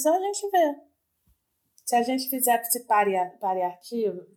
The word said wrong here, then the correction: paritário: né? Variado, vamos variar. Paritário, né? Como é que fala? Paritário. Se a gente fizesse isso aí, bora fazer.